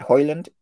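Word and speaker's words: Højlund: I just think, yeah, he's pretty much done, especially if, am I saying this right, Hoyland? – Yeah.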